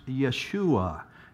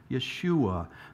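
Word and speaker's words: Yeshua (ישוע): In the original Hebrew, it would have been pronounced Yeshua. Yeshua.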